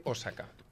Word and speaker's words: Osaka: És que, mira, pronunciarem bé, diu Osaka.